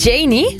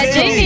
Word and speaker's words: Janie: Hey, spreek je naam goed uit, Janet? – Is het niet Janie? – Janie. – Ja, Janie. – Janie.